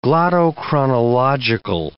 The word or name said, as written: glottochronological